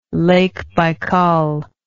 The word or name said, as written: Baikal